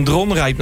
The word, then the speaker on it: Dronrijp: Een beetje, ja, we kwamen gisteren zaten we in Dronrijp natuurlijk.